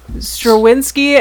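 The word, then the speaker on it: Strawinsky: Stravinsky. The Mysterious House. – Yes. – It's spelled with a W, but it's pronounced with a V.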